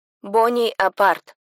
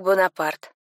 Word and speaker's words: Бонапарт: Бони апарт. Кости распались. Произносится как Бонапарт.